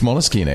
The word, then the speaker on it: Moleskine: Pronounced Moleskine? – It's Moleskine. It's an Italian company. That's why it's Moleskine.